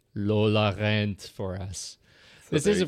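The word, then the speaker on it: Lola rennt: Did you look up in your little fancy schmancy translator device how to say the title properly? Lola Rent. – Lola Rent. – Lola Rent. – Lola Rent. R-E-N-N-T. Lola Rent.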